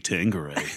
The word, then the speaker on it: Tanqueray: Tonight we Tanqueray.